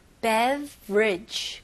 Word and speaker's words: beverage: 여기서는 스펠링이 B-E-V-E-R. 그래서 뭔가 beverage라고 하고 싶은데, 사실 이거는 원어민들은 그냥 beverage. 그래서 중간에 ER를 아예 그냥 무시해서 beverage. Bridge, 바로 V에서 R로 넘어가시면 돼요.